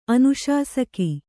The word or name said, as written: ಅನುಶಾಸಕಿ